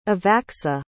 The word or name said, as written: evacsa